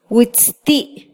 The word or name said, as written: witzti